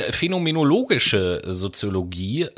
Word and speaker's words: phänomenologische Soziologie: Ja, ich äh, werde jetzt etwas erzählen über die phänomenologische Soziologie. So nennt sich das. Und ich muss die, ganz kurz sagen, das hast du sehr gut ausgesprochen.